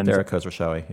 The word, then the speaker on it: Khosrowshahi: Dara Khosrowshahi, yeah.